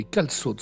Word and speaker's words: calçots: D'autres spécialités, découvertes très récemment aussi, les calçots. Alors ça s'écrit C-A-L-C-C-D-I-O-T-S.